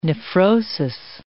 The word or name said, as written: nephrosis